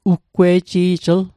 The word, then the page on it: uggwe'ji'jl: Mi'gmaq